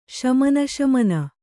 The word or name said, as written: ಶಮನಶಮನ